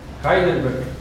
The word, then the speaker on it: Heidelberg: ¿Cómo se pronuncia? – Heidelberg.